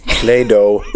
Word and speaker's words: Play-Doh: Ska vi ta bara sista då, hur man uttalar det här som hon rengjorde. Det vad hette Play-Doh.